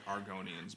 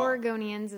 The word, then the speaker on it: Oregonians: The way you said Oregonians sounded like Argonians, by the way. – I know. I have a speech impediment. – No, well. – Oregonians is how it's pronounced.